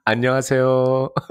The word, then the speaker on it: 안녕하세요: Annyeozheo, is that how it is? – 안녕하세요.